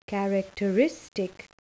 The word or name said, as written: characteristic